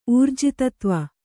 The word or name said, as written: ಊರ್ಜಿತತ್ವ